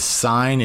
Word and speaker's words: Sign: My name there is Sign, and it is pronounced S_I_G_N.